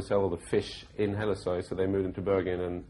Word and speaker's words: Hellesøy: My last name is pronounced in Norwegian Hellesøy. Hellesøy, it's a small island west of Bergen, where my grandfather lived. So he actually lived on Hellesøy.